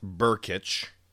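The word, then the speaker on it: Brkic: And they picked up Gabe Berkich. I think it's how you say his name. There's way more consonants than vowels. There's just an I. It's B-R-K. I-C, B-R-K-I-C. So it's pronounced Berkich.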